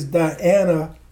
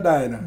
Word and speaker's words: Diana: D-I-A-N-A. – Okay, then. – Everybody, that... Diana is the European way of pronouncing Dinah. So when I went to Ghana, and we'll get into that some more, okay. – I saw someone, the nurse, with the same, spelled the same way. I said, how do you pronounce your name? She said, Dinah. So I said, well, my mother was correct.